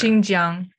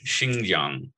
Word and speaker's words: Xinjiang: The comments come after the sportswear giant was recently hit by a backlash over statements about, is it Xinhuang? How do you pronounce that province that starts with an X? – I can't see it. Oh, up there. – Xinjiang. – I was way off. Xinjiang.